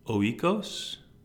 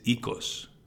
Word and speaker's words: Oikos: is not pronounced oikos. It's ikos. – Okay. – Right? Ikos means home.